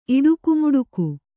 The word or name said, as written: ಇಡುಕುಮುಡುಕು